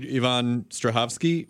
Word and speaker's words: Yvonne Strahovski: I interviewed Yvonne Strahovski once.